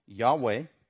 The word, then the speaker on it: Yahweh: And we'll talk about the significance of those four letters YHWH, pronounced as Yahweh, Genesis 2, 4 through 7.